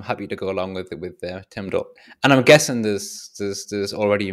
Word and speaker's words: TMDL: So it didn't take very long for the first person to say TIMDL. There we go. It's definitely easier to pronounce.